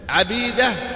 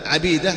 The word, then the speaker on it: عبيدة: المؤتلف والمختلف التشابه فقط في الكتابة، لكن بالنطق يختلف. عبيدة وعبيدة، انظر كيف الآن، عبيدة فتح وعبيدة، النطق اختلف لكن الكتابة واحدة.